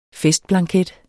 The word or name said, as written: FESTBLANKET